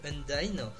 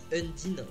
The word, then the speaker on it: Undyne: Alors moi je l'appelle Undyne. U-N-D-Y-N-E. – U-N. – D-Y-N-E, moi j'ai toujours prononcé ça Undyne, c'est peut-être Undine, je ne sais pas, je prononce ça.